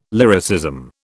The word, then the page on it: lyricism: British English